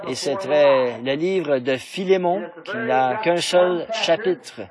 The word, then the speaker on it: Philémon: Donc, quelqu'un a dit là-bas au fond « Philémon », ce qui, je pense, est vraiment la bonne prononciation.